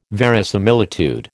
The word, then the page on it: verisimilitude: American English